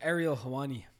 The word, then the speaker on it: Ariel Helwani: Ariel Helwani.